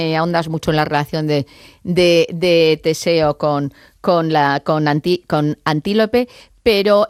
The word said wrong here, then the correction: Antíope: me gustaría no sé, lo he dicho mal el nombre, es Antíope.